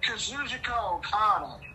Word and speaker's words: Kazuchika Okada: Oh, Kazuchika Okada.